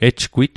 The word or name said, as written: ejgwit